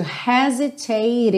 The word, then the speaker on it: hesitate: The time to hesitate is through. Atenção que esse H não é mudo, ele tem o som h, ok?